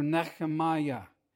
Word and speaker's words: Nehemiah: His name was Nehemiah, pronounced in Hebrew Nechemiah.